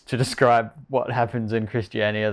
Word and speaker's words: Christiania: There's Christina in Denmark that is operated as, like... – Christiania. – Christiania. Yeah. Sorry, whatever. But, yeah. I suck at pronouncing stuff.